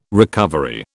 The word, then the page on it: recovery: British English